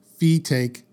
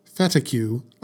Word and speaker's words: Fetaque: So, now we come to Fetaque Sanders, and it's pronounced Fetaque Sanders, not Fetacue. like I thought. Sorry, I just that's what I thought it was. I was like, that can't be right. No, it's take.